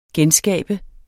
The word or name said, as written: genskabe